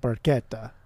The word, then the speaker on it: Barchetta: If you're reading it phonetically, it's not it's spelled barchetta, but it's pronounced barchetta.